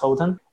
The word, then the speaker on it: Houghton: Spells H-O-U-G-H-T-O-N, but I guess I think it pronounced Holden, and the school was a Holden Academy, and so I was there.